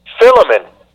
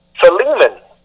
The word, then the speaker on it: Philemon: For example, I used to call this book Philemon, with the emphasis at the beginning with the P-H-I. However, after careful research, the correct pronunciation of the word in English is Philemon, with the emphasis on the L-E.